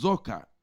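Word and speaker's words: zokar (זכר): And we said it's from a Hebrew word, pronounced zokar.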